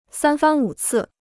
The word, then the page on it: 三番五次: sān fān wǔ cì